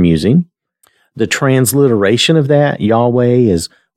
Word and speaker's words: Yahweh: Phonetically, we would say Yahweh the way I have it there.